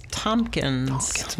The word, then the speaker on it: Tomkins: My last name is Tompkins.